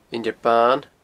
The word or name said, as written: Inbhir Ban